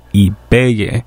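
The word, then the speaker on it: Ibeyi: And started things off with Ibiye. And that comes from a self-titled record, or I should say Ibeye.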